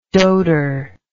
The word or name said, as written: doater